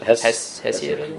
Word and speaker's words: Hesiod: So how do you pronounce it? – Hesiod.